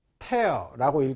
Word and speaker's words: tear: 제가 아까 말씀드렸지만 tear라고 읽기도 하고 tear라고 읽기도 하거든요. 명사일 때 tear로 읽습니다. 동사일 때 tear로 읽습니다.